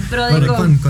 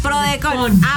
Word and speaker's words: PRODECON: Entonces, justamente existe la PRODECON. ¿Lo dije bien? – ¿PRODECON? – PRODECON. – PRODECON.